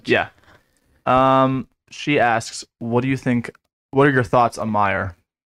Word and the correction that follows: Maher: M-A-H-E-R. I think I'm pronouncing that correctly. – M-A-H-E-R? – Yeah, is that a coach or something? – Maher. That's the Cowboys kicker.